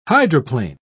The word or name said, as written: hydroplane